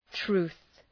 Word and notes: truth: tru:ɵ

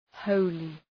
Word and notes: holy: ‘həʋlı

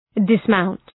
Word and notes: dismount: dıs’maʋnt

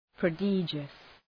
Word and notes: prodigious: prə'dıdʒəs

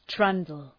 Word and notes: trundle: ‘trʌndəl

